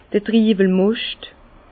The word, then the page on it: d'r Triwelmoscht: Bas-Rhin; Herrlisheim